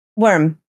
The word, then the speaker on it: Wyrm: Is it Wyrm or Wyrm? – Wyrm. – I always pronounce it just Wyrm, but W-Y-R-M.